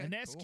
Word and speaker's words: Ionescu: Sabrina Ionescu? Did I say that right? – Yes. – Okay, Ionescu.